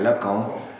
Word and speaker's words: Lacan: Noterete la pronuncia che sottolinea correttamente la nasalizzazione con cui tutti i francesi pronuncierebbero il nome Lacan senza avere la sensazione di stare, come possiamo dire, ostentando una pronuncia.